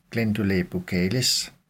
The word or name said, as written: gll. buccales